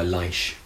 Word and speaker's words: Leish: I mean, it was hardly a nickname even, but I went by Leish. Most of my friends called me Leish. – Okay. – It's like the middle syllable of my real name.